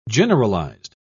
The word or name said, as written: generalized